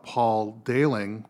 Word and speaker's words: Dayling: To help us navigate this episode about the first murder, we interviewed Paul Dayling, which rhymes with sailing.